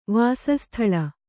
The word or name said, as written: ವಾಸಸ್ಥಲ